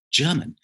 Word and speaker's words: Gherman: Yuri Gagarin, German or German Titov. I mean, it's actually, we spell it G-H-E-R-M-A-N, but it's still German.